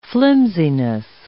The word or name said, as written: flimsiness